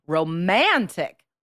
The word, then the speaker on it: romantic: You're stressing the wrong syllable. So to get a band 7 or higher, you need to correct. You need to stress the proper syllables and say romantic movies, for example.